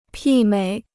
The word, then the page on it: 媲美: pì měi